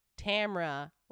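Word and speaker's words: Tamara: that it's Tamra rhymes with camera.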